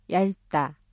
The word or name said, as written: 얇다